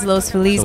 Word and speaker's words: Los Feliz: And now there's a sway back towards Los Feliz because it is more like the Spanish pronunciation.